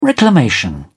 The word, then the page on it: reclamation: British English